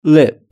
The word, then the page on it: lip: British English